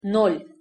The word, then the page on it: nol-: Russian